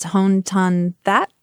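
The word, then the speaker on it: Hoan Ton-That: Okay, I think it's pronounced Hon Ton That. Do I have that right? – I think so.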